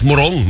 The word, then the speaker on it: Morong: Ano ba ang tamang bigkas din sa kanila? Morong o Morong? Kasi may sa Rizal, meron din eh. O, para sa parehong bigkas, Morong, mabilis.